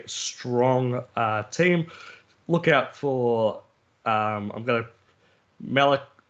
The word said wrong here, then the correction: Malachi: I think it's Malachi. It's spelt a bit different.